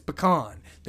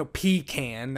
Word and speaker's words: pecan: How do you say pecan? And it's pecan. There's no pecan.